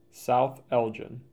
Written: South Elgin

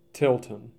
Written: Tilton

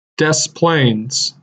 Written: Des Plaines

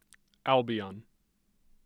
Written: Albion

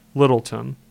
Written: Littleton

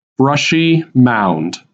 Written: Brushy Mound

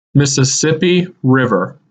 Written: Mississippi River

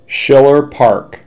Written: Schiller Park

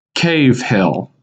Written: Cave Hill

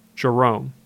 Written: Jerome

